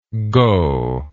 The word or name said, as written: gogh